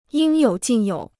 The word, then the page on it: 应有尽有: yīng yǒu jìn yǒu